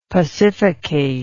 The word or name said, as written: pacificate